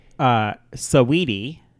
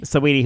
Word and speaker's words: Saweetie: Saweetie. – Saweetie? – Saweetie, whose name is spelled S-A-W-E-E-T-I-E.